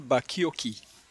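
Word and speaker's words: Bacchiocchi: tengo una cita aquí interesante del doctor Samuel Bakioki. Supongo que algunos lo conocen, ¿no? O conocen sus escritos. Samuel Bakioki. Se escribe Bacciochi, pero entiendo que se lee Bacciochi.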